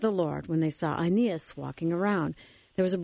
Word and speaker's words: Aeneas: I think I'm saying that wrong. How do you say it again? – It's like Aeneas. – Aeneas, who'd been paralyzed and bedridden for 8 years.